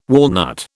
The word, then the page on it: walnut: British English